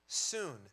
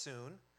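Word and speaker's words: soon (σύν): That word, understand, is the Greek word soon, spelled S-U-N like the sun, but it's pronounced soon.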